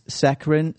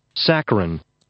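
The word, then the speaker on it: saccharine: My girlfriend loved the movie, but I thought it was ruined by its saccharin sweet ending. – Saccharin.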